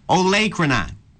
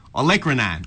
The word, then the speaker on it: olecranon: Olecranon. Oh, no, it's not. That's not it. Olecranon. That's what it is. Olecranon. That's how you say it.